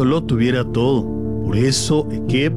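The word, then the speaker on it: Ekev (עקב): Se pronuncia así, Ekev, con acento en la primera E, y su significado principal en Deuteronomio 7:12 es por cuanto, a causa de, como consecuencia de o como resultado de.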